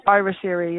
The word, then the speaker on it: Eartheater: Even like with your album, I was listening to Trinity, and I was listening to make sure I'm pronouncing this right. It's Eartheater. Is that correct? – Eartheater. Yeah.